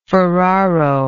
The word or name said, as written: Ferraro